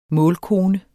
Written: målkone

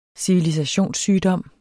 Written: civilisationssygdom

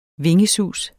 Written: Vingesus

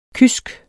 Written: kysk

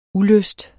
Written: ulyst

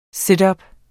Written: situp

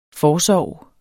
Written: forsorg

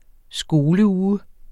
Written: skoleuge

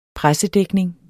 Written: pressedækning

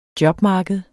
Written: jobmarked

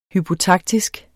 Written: hypotaktisk

